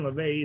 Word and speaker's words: Levesque: Yeah, Ryan LeVay. Yeah, I think his name is Ryan LeVay. It's spelled Levesque.